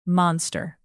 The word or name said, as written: monster